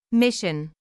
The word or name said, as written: mission